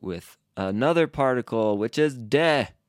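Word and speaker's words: de (で): D-E. Not D-A-Y, huh? I wonder how many people like see this and they think like day. Let's like compare the pronunciation of those real quick. We have like the English word day, but this is de.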